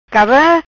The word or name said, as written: Cava